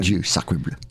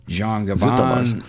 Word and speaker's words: Jean Gabin: I'm looking for my notes here because I want to talk about how do you say it? Jean Gabin. I want to talk about Jean Gabin. – Jean Gabin. Yes.